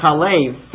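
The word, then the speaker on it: chalev (חֵלֶב): So this Hebrew word can be pronounced chalav, milk, or chalev, fat.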